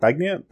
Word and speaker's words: Beignets: Beignets. – Beignets.